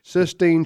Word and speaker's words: Sistine: Not 16th Chapel, people. Okay. – And had the first LASIK surgery from all that paint dripping in his eye. – Show some culture. Sistine Chapel.